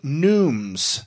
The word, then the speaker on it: neumes: These were called neumes. These were called neumes, not notes. And neumes is spelled N-E-U-M-E-S. That's neumes. So if you ever see a chant,